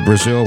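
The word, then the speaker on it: Brasil: Visited Brazil when I was a teenager with my parents and learned that it's pronounced Brazil in Portuguese.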